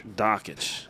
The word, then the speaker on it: Dakich: A lot of people say Dakich. It is Dan Dakich. – Dakich.